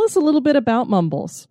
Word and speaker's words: Mumbles: Now, is this pronounced Mumbles? – Mumbles, yes. It is English.